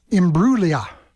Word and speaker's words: Imbruglia: Her name is actually pronounced Imbruglia. Natalie Imbruglia. The G is silent.